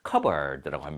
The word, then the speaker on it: cupboard: 근데 이거를 cupboard라고 하지 않습니다. cupboard라고 합니다. 즉 P 발음이 묵음이다라고 생각하시면 됩니다.